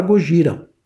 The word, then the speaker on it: Gojira: Em 2001 a banda teve que mudar de nome para Gojira, que é como os japoneses pronunciam Godzilla.